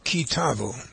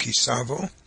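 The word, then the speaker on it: Ki Tavo: The parsha that we're going to study today is Kitavo, sometimes pronounced Kisavo, Book of Deuteronomy.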